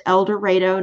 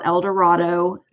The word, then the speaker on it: El Dorado: And yes, it's pronounced El Dorado, not El Dorado.